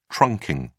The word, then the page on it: trunking: British English